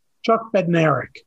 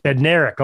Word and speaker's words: Bednarek: Chuck Bednarek. – Bednarek, okay. I was making one of the consonants silent.